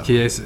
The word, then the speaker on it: Chiesa: Chiesa? – Oh, Michael Chiesa, I think. – Chiesa? – Yeah, whatever his name is. Yeah, it's like a key. Yeah, it's not cheese. It's not how it sounds.